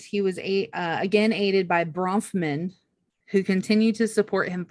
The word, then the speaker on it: Bronfman: So this was paid by Barbara Brofman, a Montreal socialite who married into the wealthy Bronfman. How do you say that? – Bronfman. No, you're adding like extra syllables. I think it's just Bronfman.